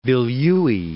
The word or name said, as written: Vilyuy